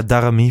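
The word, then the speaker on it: Daramy: Spreek je zijn naam zo uit trouwens? – Want ja, Darami volgens mij. Darami. Dat, ja, okay, ik dacht Danny Darami. Zegt dat elke keer. Ik zeg ook liever Darami.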